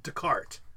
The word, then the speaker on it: Descartes: and then some of them say that if the name ends in S, but you don't pronounce the S, like Descartes.